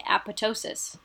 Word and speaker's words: apoptosis: So I say apoptosis. A lot of people say apoptosis.